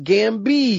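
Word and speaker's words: Gambit: It's funny looking back, because looking at all the things they got wrong, like, his name's pronounced Gambi because he's French.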